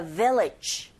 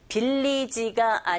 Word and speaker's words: village: Now try the second listening point, which is where the village people pray. 어려운 건 없어요. The를 제외하고 다 내용 옵니다. 빌리지가 아니라 여러분 발음하실 때 윗니로 아랫입술 긁으시면서 village 그리고 people 뒤에 푸 자체가 된소리 현상이 일어나서 people 그리고 pray.